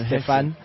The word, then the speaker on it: Stephane: So my name is Stephan Mare. It's spelled Stephane. So it's almost like Stephanie, but it's Stefan.